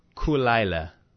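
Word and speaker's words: Caol Ila: And this one is pronounced Kulaila. – Kulaila. – It's two words. Kulaila. Kulaila. – Kulaila. – It almost... feels Jamaican when you say it like that. Oh, it's Kulaila, man, you know. But it's spelled C-A-O-L. Cool. – Space. – I-L-A. Isla.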